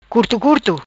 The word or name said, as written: Curto Curto